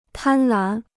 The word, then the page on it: 贪婪: tān lán